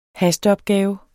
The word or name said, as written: hasteopgave